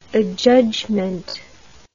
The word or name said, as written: adjudgment